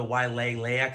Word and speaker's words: Wailea: If you want to add the extra syllable, which sometimes Hawaiians do, the Wailea Country Club, so there's like kind of two at the end of it.